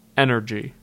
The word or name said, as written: Energy